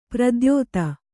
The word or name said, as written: ಪ್ರದ್ಯೋತ